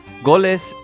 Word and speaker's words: goles: Goles es una palabra que procede del hebreo galut, exilio, en pronunciación yiddish.